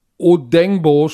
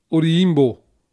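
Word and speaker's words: Odeyingbo: Ich weiß, Odenbo sprechen die Amerikaner aus. was verkehrt ist. Der Junge kommt aus Nigeria, so spricht man den Namen nicht aus. – Wie dann? – Nur weil, wenn dann wieder ein paar... Odiyimbo, nicht Odengbo. So sprechen das die Amerikaner aus, was gedacht. Verkehrt ist.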